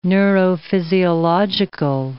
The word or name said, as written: neurophysiological